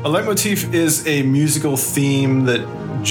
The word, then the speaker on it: leitmotif: A leitmotif? – Yeah.